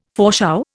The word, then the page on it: foreshow: Indian